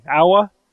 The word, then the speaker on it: aua: Germany, it's an A-U-A, awa.